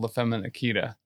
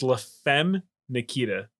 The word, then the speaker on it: La Femme Nikita: I always thought it was called La Femme Nikita. – It's La Femme Nikita. – La Femme Nikita. – Yes. – Where's the day? – What? You were just adding the syllables to that title for some reason.